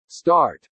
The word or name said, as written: start